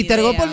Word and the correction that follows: Telgopor: no sé cómo se dice. ¿Cómo es Telgopor? – Telgopor, tela gomosa porosa.